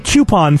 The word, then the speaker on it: coupon: I said coupon for a long time, and Autumn told me that I was getting it wrong. For some reason, all my life, I've thought that the O in coupon is silent, and apparently it's not.